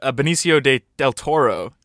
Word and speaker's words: Benicio del Toro: Yeah, I went up to Benicio del Toro.